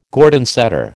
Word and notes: Gordon setter: American English

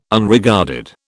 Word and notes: Unregarded: British English

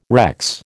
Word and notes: Rex: American English

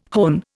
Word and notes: hone: Indian

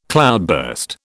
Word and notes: cloudburst: British English

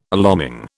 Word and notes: alarming: British English

